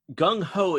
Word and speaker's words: gung ho: Per Wikipedia, now, Gung Ho is an English term rhyming with slung low with the current meaning of enthusiastic or overzealous.